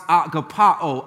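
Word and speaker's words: agapaō: This word love here, the verb in the Greek is pronounced agapao. Agapao is where we get our word agape.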